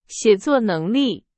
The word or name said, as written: 写作